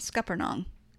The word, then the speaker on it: scuppernong: And I feel confident in saying scuppernong. Am I saying it right? – You are saying it right.